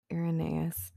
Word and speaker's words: Irenaeus: The quote I'd like to end with is by St. Irenaeus. Is that how you say it? – Irenaeus. I think either one probably works. – St. Irenaeus.